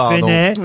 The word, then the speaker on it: Peynet: Benet, ça s'écrit P-E-Y-N-E-T.